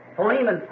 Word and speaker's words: Philemon: So, Philemon, someone said back there. Which I think is really the correct pronunciation of it.